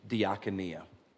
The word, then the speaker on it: diakonia: It's a Greek word that gets translated in a number of different ways, but it's pronounced in its original language, diakonia.